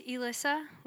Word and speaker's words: Elyssa: So, yeah, my name's Elyssa with an E. A lot of people are like, they don't know how to spell it or say it. And they're like, is it Elyssa, Elsa?